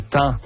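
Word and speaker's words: taon: ou ce qu'on appelle également les thans. Ou les thons, là. Il y a les deux prononciations, on va dire, au Québec qui sont acceptées.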